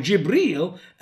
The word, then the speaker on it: Jibril: How can the interpretation for the word angels is now singular, which is one angel, Angel Gabriel, or Jibril, as Muslims pronounce it in the Arabic Quran.